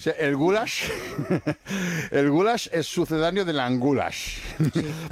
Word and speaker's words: goulash: Es que ni siquiera es una carne, es el goulash es pronunciado así, goulash.